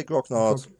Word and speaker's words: Grognard: Is that how you pronounce that word? Grognard? Grognard? – I would say Grognard.